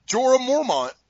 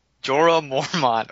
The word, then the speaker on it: Jorah Mormont: Jorah Mormont. – Jorah Mormont.